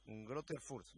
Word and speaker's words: Greuther Fürth: Greuther Fürth. Se pronuncia Greuther Fürth, me dice David.